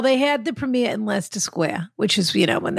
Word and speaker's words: Leicester Square: Leicester Square, I think, is how you say it, but yeah. – Yes, that's right. Square. – Leicester. – Uh-huh. Leicester Square. – Leicester Square. – You might know it as Leicester Square.